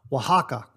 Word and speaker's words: Oaxaca: O-A-X-A-C-A. Now, the only reason why I got the pronunciation correct is because I have it written phonetically here on the screen as W-A-H-H-A-H-K-A-H. Oaxaca. And that's how this is pronounced. At least that's what my research tells me. It's pronounced Oaxaca.